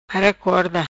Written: Mara Corda

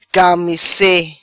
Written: Camesse

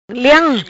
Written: Leam

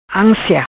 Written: Ansia

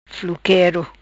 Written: Fluquero

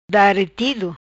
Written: Daretido

